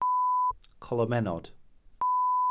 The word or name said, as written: colomennod